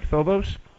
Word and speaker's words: Phobos: And am I saying that right, Phobos? – Yes.